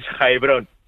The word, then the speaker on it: Heilbronn: Heilbronn.